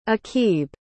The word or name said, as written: aqib